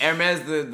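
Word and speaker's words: Hermès: Hermes, the brand is a French. – Like in French, you don't pronounce the H.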